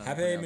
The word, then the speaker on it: Pape: Pape Machinery. – Pape Machinery. – Did you? You wrote the story. – Yes. – Did you get confirmation on how to pronounce Pape? – You're pronouncing it correctly.